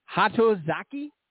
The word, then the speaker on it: Hatozaki: It's Hatozaki, the finest Japanese whiskey. – Sounds like you got the pronunciation fairly close.